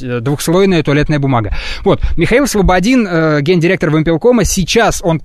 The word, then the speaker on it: Слободин: И СКР сейчас интересуется местом пребывания Михаила Слободина. Это гендиректор Вымпелкома. С ударением я правильно разобрался? Слободин, да.